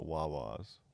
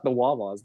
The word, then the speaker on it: Wawa: The Wawa's. – Well, it's not the Wawa's. There's no the, and it's not. Yes, it's just Wawa.